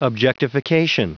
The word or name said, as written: objectification